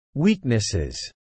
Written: Weaknesses

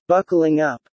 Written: buckling up

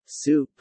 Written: soup

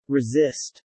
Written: resist

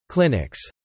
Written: clinics

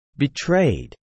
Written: betrayed

